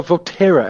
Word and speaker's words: Valterra: And most notable is Valterra, I think is how you pronounce it, platinum. What can you tell us about that? – Yeah, Valterra, and I'm going to take your pronunciation on a Jeremy evening.